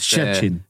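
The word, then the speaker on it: Szczecin: Jag tror väl så här att, eh, eller jag skrev här för någon vecka sedan att eh, Jensas klubb, jag kan inte uttala det där, Pogon Chesny någonting, ja. – hade ja. lagt... Tjetjin. Ja, Tjetjin.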